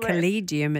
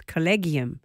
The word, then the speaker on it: collegium: Now, I got it wrong. I said collegium. It's collegium.